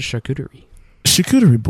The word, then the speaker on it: charcuterie: Joe likes a good charcuterie board.